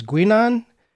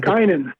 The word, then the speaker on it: Guinan: His latest book is Texas, and I'm not sure how to pronounce this, Texas Guinan and Guinan. – Guinan, Texas Guinan, G-U-I-N-A-N, and Prohibition New York, Gotham's Secret Nightlife in the Roaring Twenties, is just out from the History Press.